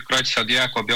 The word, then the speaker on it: Creutzfeldt-Jakob: É essa mesma pronúncia para a gente? – É, Creutzfeldt-Jakob, Jakob. Tanto faz.